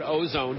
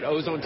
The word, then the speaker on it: Auzone: And then finally, what's the best website for our listeners to learn more about Ozone? And we should clarify that Ozone Technologies is spelled A-U-Z-O-N-E, not ozone as in the ozone layer, but clever play on words, by the way.